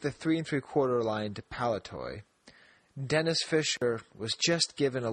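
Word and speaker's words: Denys: First of all, he has this information about Denny's Fisher, which is pronounced Dennis.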